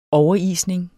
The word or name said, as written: overisning